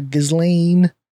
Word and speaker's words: Ghislaine: Ghislaine.